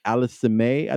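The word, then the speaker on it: Aliassime: His name is Felix Augustus Alisime, I think is how you pronounce his name.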